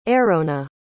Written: aerona

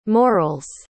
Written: moral's

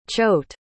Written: choate

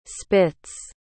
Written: spits

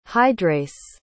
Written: hydrase

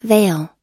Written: vale

